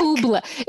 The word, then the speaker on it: oobleck: Oobleck. It's oobleck. I think it's spelled O-O-B-L-E-C-K.